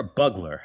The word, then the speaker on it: bugler: Is it Bugler or Bugler? How do you actually pronounce this word? – it's definitely Bugler.